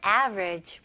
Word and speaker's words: average: 이 단어는 average 이렇게 세 음절로도 물론 표현할 수 있겠지만 원어민들은 주로 이음절로 합니다. 그래서 여기 보시면 A, V, E, R 있잖아요. 그래서 E를 그냥 생략을 해서 A 첫 음절, 그 다음에 그냥 두 번째 음절은 ridge, ridge. 오케이, okay? 다리 할 때, bridge 이잖아요. 여기서는 그냥 V 발음으로 average, average. 오케이, okay? 여기서도 강세가 첫 음절에 들어갑니다.